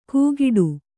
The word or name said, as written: ಗೂಗಿಡು